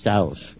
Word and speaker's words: Taos: Taos? – Taos.